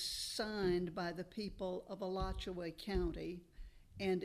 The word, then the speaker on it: Alachua: And in 1949, they built a house on Alachua Street between 16th and 17th Street. And I must tell you, the real pronunciation of A-L-A-C-H-U-A is Alachua.